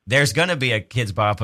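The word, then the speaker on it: Kidz Bop: She mostly listens to Kid Bop's radio. – Kid Bop's. – Kid Bop's. Oh, that's such an uncle thing to say. It's Kids Bop, yeah.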